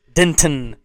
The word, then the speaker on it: Denton: No one really emphasizes the T. Denton.